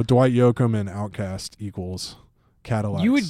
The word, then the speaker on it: Cadillacs: I mean, the first album and the first hit song is called Guitars Cadillacs. – Yeah. – Emphasis on the lax.